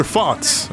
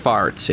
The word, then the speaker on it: Fotz: Can you hear me, Mr. Fotz? Am I pronouncing that correctly? – That's actually pronounced farts. It's French.